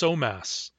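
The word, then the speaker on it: Somass: But we also find an English pronunciation of the native name, which in English is pronounced Somas.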